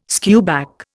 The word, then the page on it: skewback: Indian